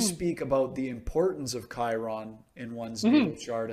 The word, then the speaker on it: Chiron: about is it Chiron or Chiron? I don't know how to pronounce that one. Chiron. – Yeah. Chiron.